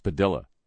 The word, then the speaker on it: Padilla: Jose Padilla, or Padilla, as he apparently insists on being called. That's why we held him in the brig for 3 years, because he insisted on it being pronounced Padilla.